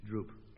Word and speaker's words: drupe: Dillis, yours is a droop, and it's spelt D-R-U-P-E, droop.